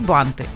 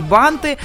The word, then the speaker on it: банты: Значит, друзья, есть несколько слов, которые достаточно популярные в обиходе, но в которых часто неправильно ставят ударение. Это торты и банты. Да, чаще всего люди говорят торты и банты. А принцип тот же. Ударение из существительного никуда не убегает. Торты и банты.